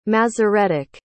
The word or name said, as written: Masoretic